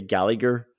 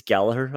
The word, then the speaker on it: Gallagher: You said Matip and uh, I hear a lot of commentators and English people say Gallagher. I have to tell you, it's Gallagher.